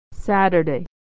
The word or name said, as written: Saturday